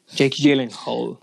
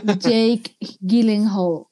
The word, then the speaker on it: Jake Gyllenhaal: y Jack Hall o como se pronuncie. – Jake Gyllenhaal. Jake Gyllenhaal.